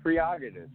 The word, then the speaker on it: prerogative: Yeah, prerogative. That's the correct word. – No. Where'd you get that? – It is. We've been mispronouncing it for ages. It's I guess you're right. Prerogative. – Yeah. Prerogative. Not prerogative.